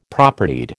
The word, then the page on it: Propertied: American English